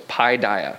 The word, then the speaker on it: paideia: And if I say paideia or whatever, it's because I memorized the incorrect pronunciation. I'm trying to correct myself. So it's paideia, if anybody's interested.